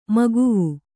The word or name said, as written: ಮಗವು